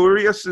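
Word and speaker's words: Urías: I've heard both for Urias and Urias, so it's definitely not a yes sound at the beginning. It's a ooh.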